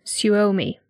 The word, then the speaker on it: Suomi: Suomi, which that is how the lady on Google Translate said it.